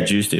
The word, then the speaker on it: Giusti: Am I pronouncing it right? – Yeah, Giusti. Okay, that was good.